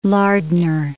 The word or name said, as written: Lardner